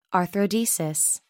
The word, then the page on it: arthrodesis: American English